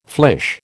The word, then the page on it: flèche: American English